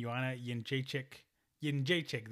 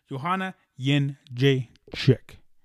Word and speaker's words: Jędrzejczyk: I like Joanna champion, Joanna Jędrzejczyk. There we go. I said it right. Joanna Jędrzejczyk.